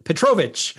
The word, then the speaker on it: Petrovic: Well, Kevin and again, let me make sure I pronounce it Petrovic.